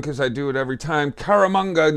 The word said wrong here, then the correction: Caramagna: Nah. – Close. I think it's Caramagna. – Caramagna. Yes, Italian. – Yeah, like, Caramagna. You need mayonnaise.